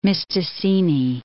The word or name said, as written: Mistassini